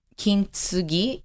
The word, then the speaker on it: Kintsugi: So in Japanese, they say su, right? – Kintsugi, wellness. Yeah. So it's like a silent T-S-U.